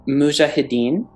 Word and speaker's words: Mujahideen: Is it Mujahideen? – Mm-hmm. Yeah, that's perfect.